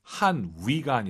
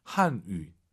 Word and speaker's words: 汉语: 한 위가 아니라 YU 발음은 입술을 동그랗게 말아서 쇼한유. 한유, 예, 위 하시면 안 돼요.